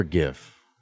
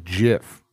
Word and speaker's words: GIF: Also, if GIF is an acronym, short for graphic integrated format, graphic is not pronounced graphic. – Yeah. – Why would it be GIF? You know? – Yeah. – Like the word that it's short for does not have the soft G sound.